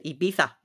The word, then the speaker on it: Ibiza: And if you're really cool, you pronounce it Ibiza.